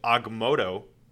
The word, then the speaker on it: Agamotto: But Scott's actually getting through to Matthew, and then Magic shows up with the Eye of Agamotto.